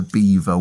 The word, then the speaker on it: Belvoir: We'll finish up today's episode with a story and poem sent in by Andy, who told us about the Beaver Witches. That's spelled not like the animal beaver, but B-E-L-V-O-I-R.